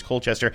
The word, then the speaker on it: Colchester: But I'm pretty sure that Colchester is Colchester.